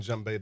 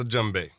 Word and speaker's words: djembe: D-J-E-M-B-E. – That's the name of the instrument? – That's a djembe. – Wrote a song about it. Want to hear it? Here it goes. – Did you know that? – No. – Okay. – The D is silent.